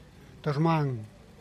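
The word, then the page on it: durmang: Teotitlán del Valle Zapotec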